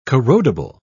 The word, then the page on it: corrodible: British English